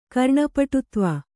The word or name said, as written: ಕರ್ಣಪಟುತ್ವ